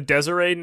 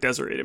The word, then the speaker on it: Deseret: So in the, I believe it's the Deseret News. It's spelled D-E-S-E-R-E-T. Sounds like Deseret to me.